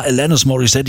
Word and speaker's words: Alanis Morissette: Alani Morissette? Was ist das? Alanis? Wie spricht man die denn aus? Irgendwie, ne? Bis dann irgendwann hieß, ja, Alanis Morissette, die kommt aus Kanada, englische Aussprache und so.